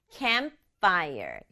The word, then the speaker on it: campfire: camp랑 fire이 붙은 말이라서 앞에 있는 camp에다가 강조 줘서 campfire. 이렇게 두 박자로 발음해요.